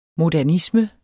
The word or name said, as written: modernisme